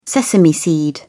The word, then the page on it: sesame seed: British English